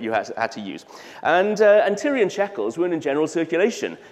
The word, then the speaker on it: Tyrian: You had to use something called a Tyrolean shekel. Sorry, Tyrolean, I said that wrong. A Tyrian shekel.